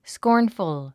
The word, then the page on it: scornful: skɔ́ː r nfəl; スコーンフル